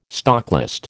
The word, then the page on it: stocklist: American English